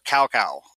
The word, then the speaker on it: Cao Cao: For example, there's a character in the game called Zhao Zhao, or Cao Cao is how you might actually say it. And his name in the game is spelled C-A-O-C-A-O. In the book, I know who that guy is, but his name in the book is spelled T-S-A-O. But it's said the same way.